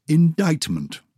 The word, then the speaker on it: indictment: C. Indictment. Muscle. Silent C.